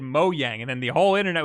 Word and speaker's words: Mojang: It's both. It's Swedish, so in Swedish it's Mojang, yeah. But in every other country, they just read it out as Mojang.